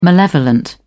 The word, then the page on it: malevolent: British English